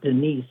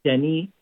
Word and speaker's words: Denis: I'll also be talking about the other saint of the day, St. Denis, or I think in French they pronounce it St. Denis, and not Denise, but Denny, and he was the first bishop of Paris.